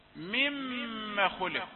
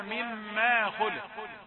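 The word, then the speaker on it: مم خلق: مما خلق، البعض ينطقها مما خلق، لا، لكن هي مما خلق، ميم مشددة ليس بعدها ألف، حتى عندما تختبر في الوقف عليها للاختبار مم.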